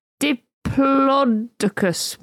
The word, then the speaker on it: Diplodocus: Both pronunciations are acceptable. – Oh. – A BBC science correspondent said, I surveyed paleontologists last year and said, Diplodocus won. However, to make matters more confusing, other experts at the Natural History Museum offer a third version. Diplodocus.